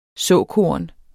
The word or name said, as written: såkorn